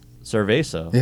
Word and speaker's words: cerveza: Cerveza.